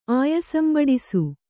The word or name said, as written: ಆಯಸಂಬಡಿಸು